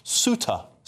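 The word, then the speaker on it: Ceuta: Apparently, that place is, James has said here, James H said it's pronounced Souta.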